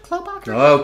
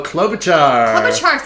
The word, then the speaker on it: Klobuchar: I'm going to say her name right. Is it Klobuchar? – Klobuchar. – Klobuchar. Thank you.